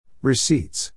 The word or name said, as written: receipts